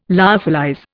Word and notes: lyophilize: Indian

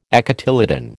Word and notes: acotyledon: American English

